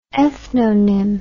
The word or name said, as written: ethnonym